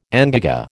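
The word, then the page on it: engagé: American English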